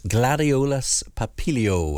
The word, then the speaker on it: Gladiolus papilio: Adam Frost's Bulb of the Month, his choice, Gladiolus papilio. Let me spell it for you. We normally do Gladiolus and papilio, Papilio. Gladiolus papilio, or butterfly sword lily.